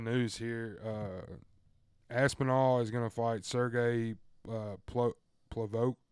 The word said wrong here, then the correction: Pavlovich: Sergey Pavlovich, September 4th.